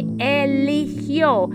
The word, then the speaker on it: eligió: You can also say eligió. Can you say after me? Try to say it. Eligió.